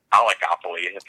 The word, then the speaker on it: oligopoly: There's only what three or four really big companies, and it's called an oligopoly.